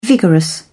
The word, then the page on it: vigorous: British English